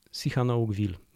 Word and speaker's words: Sihanoukville: Sihanoukville, mniej więcej tak się wymawia poprawnie, ale oczywiście mogłem popełnić błąd, bo te nazwy azjatyckie są dla nas nieco trudniejsze, jeżeli chodzi o wymowę.